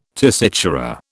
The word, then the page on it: tessitura: British English